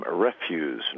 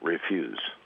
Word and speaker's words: refuse: Hi, that's Dillingham Refuse, not Refuse.